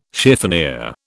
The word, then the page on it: chiffonier: British English